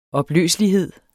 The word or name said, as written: opløselighed